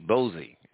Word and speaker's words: Boaz: How do you spell it, the one you're talking about? – B-O-A-Z, Bozy.